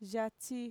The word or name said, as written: yatxi